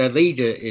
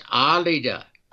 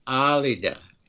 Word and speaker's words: Alida: Her name is the way when I first met her, I had to learn it because, yes, Alida is how English people would pronounce it. No, it's Alida, with the accent on the first A, Alida.